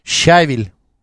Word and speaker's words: щавель: Щавель. Видишь? Щавель. Щавель? – Да, вот видишь, это ударение, Макс. Щавель.